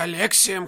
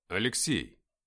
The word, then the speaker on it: Алексей: «А тебя Алексием кличут!» Он произнес мое имя по-иному, сделав ударение на первом слоге. – «Алексей!» — сказал я в правильной форме.